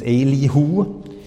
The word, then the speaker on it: Elihu: Elihu, I don't know how you pronounce his name in English, but in Hebrew it's Elihu.